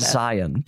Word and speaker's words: Zion: If you're trying to infiltrate the Utah community, you're going to want to say it. Zion. Do the lazy pronunciation of Zion.